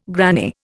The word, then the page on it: granny: Indian